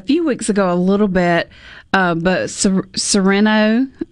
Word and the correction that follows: Cyrano: um, and I'm not saying his name right, but it's a classic love story of this guy who, because of the way he looks, Cyrano um, de Bergerac. – Cyrano, thank you.